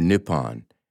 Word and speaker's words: Nippon: which is pronounced Nihon or Nippon.